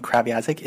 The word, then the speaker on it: Kreviazuk: 98 is Chantal Kreviazuk. That's how you pronounce her last name. She's Ukrainian, by the way. Uh, she's from Winnipeg, and she has a really, really uh, interesting last name. Kraviazik is how you pronounce it.